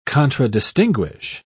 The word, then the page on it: contradistinguish: British English